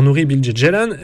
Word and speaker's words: Nuri Bilge Ceylan: à commencer par Nuri Bilge Ceylan, l'ai-je bien prononcé Florent ? – C'est magnifique.